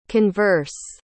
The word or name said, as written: converse